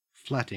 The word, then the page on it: flatting: Australian English